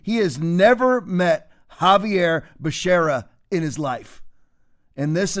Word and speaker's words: Becerra: For Secretary of Health and Education Services, I nominated Javier Bakiria. You know, Javier Becerra, excuse me.